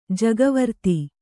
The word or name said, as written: ಜಗವರ್ತಿ